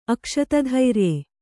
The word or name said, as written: ಅಕ್ಷತಧೈರ್ಯ